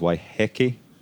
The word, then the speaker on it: Waiheke: So actually, ooh, properly the Maori, it's of course a Maori word, the Maori pronunciation is Waiheke.